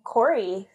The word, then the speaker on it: Cory: Cory. C O R Y. – Lily Cory. – I'm just used to like I'm just used to like people doing it with the E,